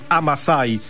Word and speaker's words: Amasai: No właśnie, dziękujemy ci, Marku, za płytę zespołu Amasai. No powiedzmy, że tak to powinno się wymawiać.